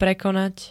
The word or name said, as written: prekonať